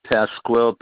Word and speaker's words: Pasquale: Pasquale, P-A-S-Q-U-A-L-E, Messina, M-E-S-S-I-N-A.